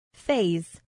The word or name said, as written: phase